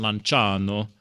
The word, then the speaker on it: Lanciano: For example, if we take the Eucharistic miracle of Lanciano, or Lanciano, I usually do the Italian pronunciation, Lanciano, Italy, we find a similar narrative to what we find with Bolsena.